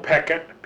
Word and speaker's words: Pechet: Mel Peckett, P-E-C-H-E-T, didn't pronounce it in the French manner, did geology, he was Harvard.